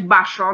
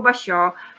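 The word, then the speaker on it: Bashō: Na początku prosta rzecz, ale ważna, mianowicie kwestia samej wymowy, tak, żeby nie czytać baszo na przykład, tylko basio czy nie kaczoga,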